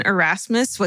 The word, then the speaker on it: Erasmus: when she met a man named Ursmus Stribling Trout Shoe. – No. – Yes. – No. – Erase, his first name is spelled E-R-A-S-M-U-S. – Erasmus.